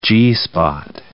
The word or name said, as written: G-spot